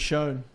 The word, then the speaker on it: Michonne: Michonne. – Michonne miniseries spinoff.